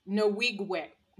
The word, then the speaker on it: Nwigwe: Just for clarification, the way to pronounce it is Nwigwe. Nwigwe. – Like Norwegian. So that's how I remember.